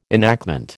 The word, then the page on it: enactment: American English